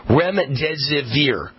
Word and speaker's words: remdesivir: Remdesivir.